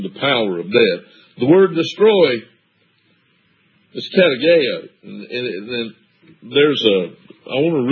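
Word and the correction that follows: Katargeo (καταργέω): Katargeo. I'm sorry, I mispronounced it. Katargeo.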